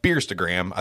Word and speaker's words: Beerstagram: And speaking of that, so do you, as you Beerstagram, I think that's a thing, right? Like, Heck yeah. – I'm saying it right? Okay. Yep.